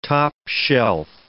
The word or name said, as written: top-shelf